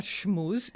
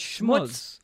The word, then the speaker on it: schmooze: It's not schmooze. It's schmutz. Now, he may be technically correct.